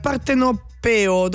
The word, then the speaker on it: partenopeo: Questa è tutta musica italiana, perché Lo Strachan è un DJ producer siciliano, e invece Koshana de è un producer partenopeo. Dove va l'accento? Partenopeo, era giusta la prima.